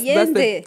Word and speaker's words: Allende: Allende. I believe that's how you say it. – That's Spanish. Because two L's is ye. – Oh, yeah. That's, Allende.